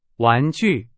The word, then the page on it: 玩具: Chinese